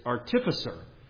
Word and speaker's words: artificer: And he is described here as an instructor of every I want to say artificer, but actually someone in the conference read a passage in 2 Chronicles and they pronounced it as artificer. And they said they had looked it up and that's the way it's supposed to be pronounced.